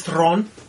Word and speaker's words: Theron: Apparently, Charlize Theron, which is how you say it in South African,